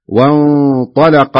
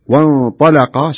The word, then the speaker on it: وانطلق: وانطلق الملأ، وانطلق، شوف الطاء مفخمة واللام مرققة والقاف مفخمة فتنطق بهذا الشكل، وانطلق، شوف اللام مرققة لأن اللام دخلت بين مفخمين.